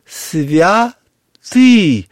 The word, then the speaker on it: святый: А на самом деле, я-то преподаю церковно-славянский язык, «святый». Ударение на «ы» на втором слоге,